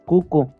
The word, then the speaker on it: Kuku: Y la forma en la que se escribe es Keke. Pero la pronunciación correcta es Cucu